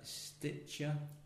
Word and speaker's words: Stitcher: If you're listening to this in America on Stitcher.